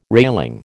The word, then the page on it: railing: American English